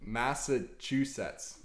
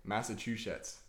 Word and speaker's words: Massachusetts: Not chewsh. Chew. Set. Like two separate. Chewsets. – Massachusetts. – There you go. – Now say it together. – Massachusetts.